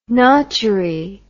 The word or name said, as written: notchery